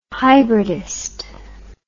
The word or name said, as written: hybridist